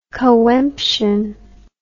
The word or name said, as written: coemption